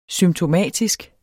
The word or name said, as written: symptomatisk